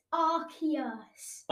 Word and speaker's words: Arceus: Archimus. – Oh, that is so, so close. It's Arceus. – Arceus, not Archimus.